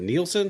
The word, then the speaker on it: Nielsen: We got Ben, is it Nielsen? Is that how you pronounce the last name? – Yes, Nielsen. – I didn't butcher it. – Just like the ratings, Nielsen.